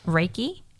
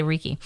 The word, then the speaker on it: Reiki: So I also went to, say it for me because I always say it wrong, Reiki. – Yes. – I usually say Reiki.